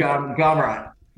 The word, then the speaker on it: Gamrot: Gamrot. – Mataj Gamrot.